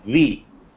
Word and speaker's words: dvi: So you protrude your tongue and touch the back of the teeth. So it will become dvi.